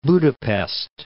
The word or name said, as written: Budapest